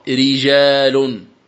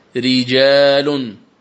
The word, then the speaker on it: رجال: قال المؤلف وفقه الله: مثال ذلك قوله تعالى رجال، فيه رجال يحبون، رجال كلمة رجال الراء مرققة وهي وقعت في أول الكلمة، الراء مرققة لأنها مكسورة، إذن تقرأ رجال، ري ري هكذا تنطق الراء المكسورة، ري رجال.